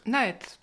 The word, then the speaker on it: NAET: C'est la méthode, alors je ne sais pas si je prononce bien, Naet. – Naet.